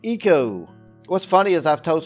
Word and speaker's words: ICO: But this game is called Eco. It's spelled ICO, and it debuted on the PlayStation 2 in September of 2001.